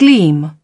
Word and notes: gleam: British English

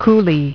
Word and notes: coulee: British English

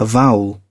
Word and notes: avowal: British English

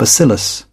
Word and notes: bacillus: British English

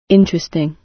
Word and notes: interesting: British English

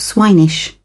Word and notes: swinish: British English

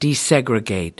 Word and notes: desegregate: American English

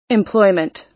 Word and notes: employment: American English

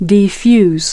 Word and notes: defuse: American English

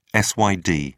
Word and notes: syd: British English

